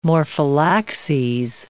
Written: morphallaxes

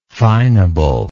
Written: fineableness

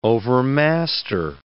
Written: overmaster

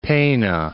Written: Peene

